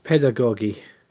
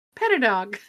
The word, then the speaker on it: pedagogy: Pedagogy. – Yeah, I put that in there because I will. Pedadog. – Yeah. Not Pedadog.